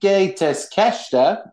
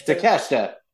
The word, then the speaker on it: Takeshita: Number 8 is Konosuke Takeshita. I said that wrong. – Takeshita.